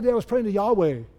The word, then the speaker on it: Yahweh: And it's pronounced, does anybody know? It's pronounced Yahweh.